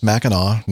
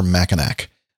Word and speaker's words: Mackinac: It's pronounced Mackinac, never Mackinac. The